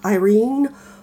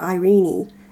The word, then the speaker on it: Irene: You'll hear it pronounced Irene or Irene Adler.